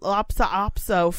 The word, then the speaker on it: Lhasa Apso: It's Hassa Apaso. Fucking what? – Oh, Lhasa Hassa Lhasa Apso.